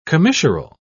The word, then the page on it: commissural: British English